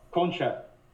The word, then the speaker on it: concha: Have you ever had the ganchas, Matt? – Ganchas? – Is that what it's called? Am I saying it right? – Oh, concha.